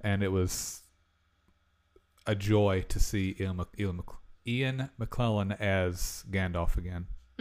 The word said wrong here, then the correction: McKellen: Is it McClellan or McKellen? Am I saying his name wrong? – I think it's McClellan. – McKellen. Ian McKellen. I've been saying his name wrong the whole time.